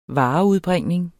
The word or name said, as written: vareudbringning